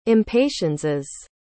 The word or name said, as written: impatienses